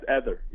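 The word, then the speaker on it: Éder: Is it Eder? Like rhymes with header? – Yes, Eder, yeah.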